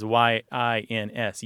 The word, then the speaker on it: yins: They tend to spell it in Pennsylvania as Y I N S, yins, but it's it's the same thing, just a slightly different pronunciation and a different spelling.